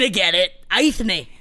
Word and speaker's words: Eithne: She was, so originally her name in Gaelic, it is spelled E-I-T-H-N-E, which looks like Aithne. – Aithne. – Aithne.